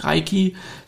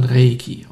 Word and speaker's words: Reiki: So ähnlich wie bei Reiki. Das heißt auch nicht Reiki, sondern Reiki.